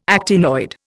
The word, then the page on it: actinoid: Indian